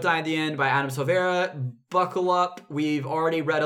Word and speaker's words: Silvera: is They Both Die at the End by Adam Silvani. – Let's confirm. – That's not correct. – I probably said it wrong. – It's by... Drummel. – Adam Silvera. – Silvera. Adam Silvera.